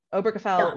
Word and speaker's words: Obergefell: I hope I'm pronouncing this right, Obergefell, is it that? Obergefell, yeah.